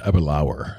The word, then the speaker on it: Aberlour: It looks like Aberlour. It's pronounced Aberlour. It rhymes with power.